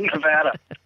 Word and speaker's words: Nevada: How do you pronounce it? – Nevada.